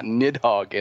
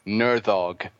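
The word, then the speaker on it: Níðhöggr: So I actually found it's like it is a reference to Norse mythology, and there is an actual pronunciation for it. It's not Nidhogg, it's Nerdhogg.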